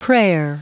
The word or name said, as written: prayer